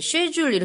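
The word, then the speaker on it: schedule: 영국 사람은 스케줄을 이제 쉐줄 이렇게 발음을 하고요.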